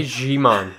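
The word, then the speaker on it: Djimon: Digimon Husso will reprise his role as... I didn't say the name correctly. – Digimon Husso. – Just say Jimon. Jimon.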